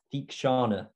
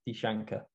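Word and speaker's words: Theekshana: And also, I keep pronouncing the Sri Lankan's name wrong. It's Thikshana, not Thikshankar.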